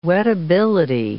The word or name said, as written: wettability